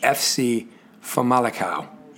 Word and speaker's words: Famalicão: So it's spelled, obviously you know how to spell FC, but then it's Famalicão. But it's that, Portuguese A with kind of this tilde over it.